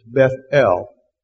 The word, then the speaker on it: Bethel: So you don't say Bethel, or Bethel, we would say it, but it's Bethel. The emphasis on both of them.